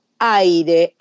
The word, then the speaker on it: Ahyre: Ellos no son nuevos, hace 15 años que están en la música y eh, forman en 2019 el grupo aire. Aire se escribe A-H-Y-R-E.